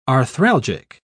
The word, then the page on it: arthralgic: British English